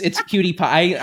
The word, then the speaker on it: PewDiePie: Is that how you say it? – Yes. – Oh my gosh, I've never actually heard someone say it, so I avoid saying it. – It's PewDiePie.